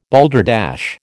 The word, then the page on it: balderdash: American English